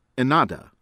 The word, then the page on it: Ennahdha: American English